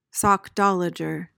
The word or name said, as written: sockdolager